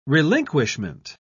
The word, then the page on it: relinquishment: British English